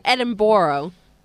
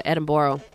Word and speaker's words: Edinburgh: Wait, well, actually, you have to call it Edinburgh. – Edinburgh. – Edinburgh. – Are you going to the Edinburgh Festival? – That's the way the Americans pronounce it, Edinburgh.